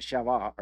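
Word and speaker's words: Sheva (שבע): I think the Jews, the Hebrews pronounce it Sheva.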